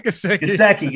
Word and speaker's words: Gesicki: No, I have Gieske. Is that how you pronounce his name? – Gajewski. That's my co-host. Mike Gesicki.